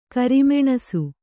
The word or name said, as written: ಕಿರುಮೆಣಸು